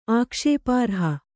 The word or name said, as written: ಆಕ್ಷೇಪಾರ್ಹ